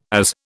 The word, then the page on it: AZ: British English